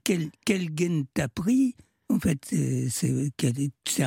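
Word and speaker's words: gen (جن): C'est-à-dire que on utilise le mot, enfin, en arabe égyptien, on ne dit pas djinn, on dit gen. – Ouais. – Parce qu'on ne prononce pas le dje, on dit ge à la place.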